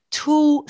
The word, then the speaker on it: tulle: Tulle skirts, that's T-U-L-L-E. I always debate how to pronounce this, but it's tulle, I've been calling it for a while.